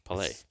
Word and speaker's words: Pelé: Yeah, got to throw that accent on the last one. – It's Pelé.